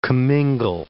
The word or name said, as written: comingle